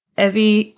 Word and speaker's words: Evvie: Evie, E-V-V-I-E. The author does the helpful thing of having the main character complain about how people mispronounce her name so that you know how it's pronounced. It's Evie like Chevy.